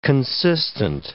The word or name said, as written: consistent